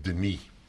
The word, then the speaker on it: Denis: One N is the French way, and it's pronounced Denis.